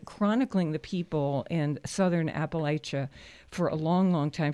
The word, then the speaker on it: Appalachia: Either pronunciation is technically correct. but in the southern Appalachians, we say Appalachia.